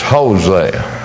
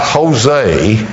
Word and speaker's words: Hosea: Right after Ezekiel is Daniel, and right after Daniel is Hosea. It's not Jose, it's Hosea, spelled with an H, not a J.